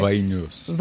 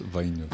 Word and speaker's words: Vainius: Vainius. – Vainius. – Antanas Vainius.